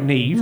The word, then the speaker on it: Neve: It's Neve. You're Neve Campbell. Your name is Neve. – No, it's pronounced Nev.